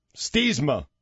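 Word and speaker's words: Stiemsma: How do you pronounce his name? Is it Steemza? – Stiesma.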